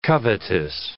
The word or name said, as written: covetous